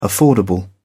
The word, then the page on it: affordable: English; British English